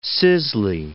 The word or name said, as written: Sisley